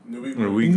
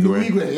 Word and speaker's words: Nwigwe: How do you say that name? Nwigwe. – Nwigwe, yeah.